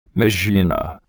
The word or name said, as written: mizuna